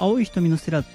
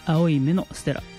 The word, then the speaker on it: 青い瞳のステラ: だから青い瞳のステラって思ってる方多いと思うんですけど、青い目のステラと読むようです。これライブで柳ジョージさんご本人が曲紹介しているので間違いないと思います。